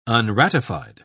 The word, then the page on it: unratified: British English